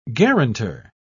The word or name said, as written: guarantor